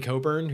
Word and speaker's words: Cockburn: Uh, I, that's like my greatest podcast regret is accidentally teaching you how to properly pronounce Kofi Cockburn's for, name. Yeah, anyway. for those who don't know, uh, Cockburn has a silent CK inexplicably after Co.